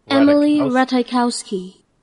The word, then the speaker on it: Ratajkowski: Emily Ratajkowski. – So the J is silent.